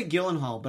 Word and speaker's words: Gyllenhaal: There are reports that Jake Gyllenhaal, some people say Gyllenhaal, but I think it's Gyllenhaal. – No one says that. – I've heard it that way. – They're saying it wrong.